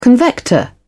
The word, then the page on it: convector: British English